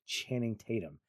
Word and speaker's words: Channing Tatum: And then you couple that with Channing Tatum, and I'm going to say Channing Tatum because my wife kept mispronouncing his name, calling him Tanum Channing or Tanning Chatham. He kept just kept doing that. And I was like, it's Channing Tatum.